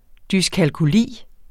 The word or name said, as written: dyskalkuli